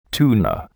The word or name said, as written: tuna